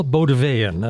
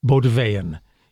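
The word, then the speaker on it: Bauldeweyn: Noel Bodevayan, and I'll spell that for you because it's not clear. B as in boy, A U L D as in dog, E W E Y E N. Bodevayan is a Franco-Flemish composer of the 16th century, early 16th century, one of the best of that time.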